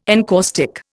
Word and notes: encaustic: Indian

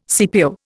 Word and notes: CPO: Indian

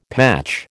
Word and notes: patch: American English

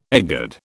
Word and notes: egad: British English

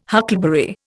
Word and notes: huckleberry: Indian

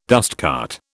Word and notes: dustcart: British English

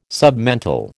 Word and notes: submental: American English

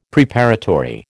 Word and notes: Preparatory: American English

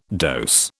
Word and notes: dose: British English

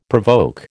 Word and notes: provoke: American English

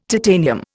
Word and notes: titanium: Indian

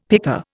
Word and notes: pika: Indian

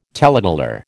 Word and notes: taleteller: American English

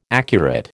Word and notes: accurate: American English

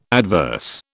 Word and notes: adverse: British English

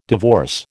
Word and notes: divorce: American English

